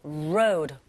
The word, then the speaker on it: road: The road, 로드 아니고요. road, 우 하시면서 road, 그리고 드 사운드가 바로 이어지는 어, 보시면 역시 드 사운드가 네, 강몸과 양몸 사이 껴있죠.